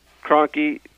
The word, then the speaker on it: Kroenke: Kroenke.